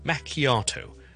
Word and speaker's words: macchiato: 嘅 espresso 啦，跟住加一啲牛奶打出嚟嘅泡，咁咧就系 macchiato， M A C C H I A T O， M A C C H I A T O。当然呢个字系意大利文嚟嘅，但系如果我哋讲英文嘅时候咧，系读 macchiato， macchiato，重音喺个 R 度，macchiato， macchiato， macchiato。咁咧就唔好咧。由於見到個 ch 咧，就讀成 chi，唔好讀成 macchiato，係 it's macchiato， macchiato， macchiato。